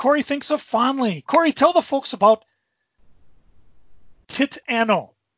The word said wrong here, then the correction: Titano: Oh, I'm sorry. I said that wrong. Titano. – Oh, Titano.